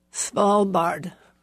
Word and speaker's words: Svalbard: Svalbard.